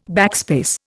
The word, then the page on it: backspace: Indian